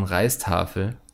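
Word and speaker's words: Rijsttafel: Bestellen Sie eine Riestafel, ausgesprochen Reistafel, und genießen Sie diese typisch indonesisch-holländische Köstlichkeit.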